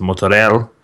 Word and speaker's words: mozzarella: You don't pronounce the A on the end of that, okay? It's mozzarella. And here I am.